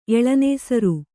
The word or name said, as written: ಎಳನೇಸರು